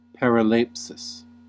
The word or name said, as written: paraleipsis